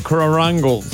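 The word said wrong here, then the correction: caruncle: That is not how you say it, I'm sure. – Carunkle. – What? – A lacrimal carunkle.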